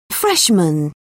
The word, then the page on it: freshman: British English